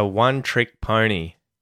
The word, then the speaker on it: one-trick pony: So, we can mute the K at the end of the word trick. One-trick pony. And we do that because there is a consonant, a P, that comes after the K.